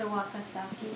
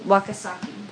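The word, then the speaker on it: Wakatsuki: And then um, next to her is um, the girl from Farewell to Manzanar, Janie I, I, don't know how to pronounce her last name. – Yes. It's a Wakasaki. – Wakasaki. – Right.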